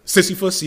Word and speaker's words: Sisyphus: Sisyphus, yeah.